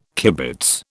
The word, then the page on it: kibitz: British English